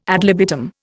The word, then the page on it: ad libitum: Indian